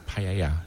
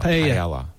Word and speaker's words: paella: And it's paella, not paella. – So double. – Yeah, that's how we pronounce double. – That. – Double L becomes a Y, basically.